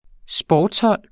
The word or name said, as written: sportshold